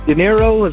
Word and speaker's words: De Niro: De Niro is like Robert De Niro.